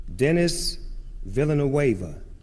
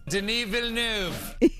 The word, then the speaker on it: Denis Villeneuve: Well, here's, like, for instance, Denis Villeneuve, the director, here's how you're supposed to pronounce it. – Denis Villeneuve.